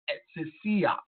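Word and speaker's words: exousia (ἐξουσία): This word power is pronounced in the Greek as exousia. Exousia. You hear me? It's pronounced as exousia.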